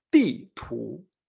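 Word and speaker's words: ti tu (地图): Mapa en chino se dice ti tu.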